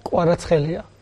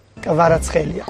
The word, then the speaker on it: Kvaratskhelia: Hvicha Kvaratskhelia. Kvaratskhelia. Kvaratskhelia. – ¿Qué te parece? – Porque él al final la H muda la convierte en J, pero bueno, es lo mismo, ¿no? – No, hombre, me hace gracia.